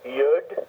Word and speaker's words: Yud: So some people write it as a Y-O-D, other people write it as a Y-U-D, but the idea is the Y. – Okay. – Now, doesn't matter whether it's U-D or O-D. It's to get the person to articulate the Y sound.